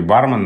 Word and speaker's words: бармен: Но в данном случае бармен норма строга и требует от нас ударения на первом слоге.